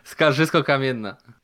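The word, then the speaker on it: Skarżysko-Kamienna: Skarżysko Kamienna.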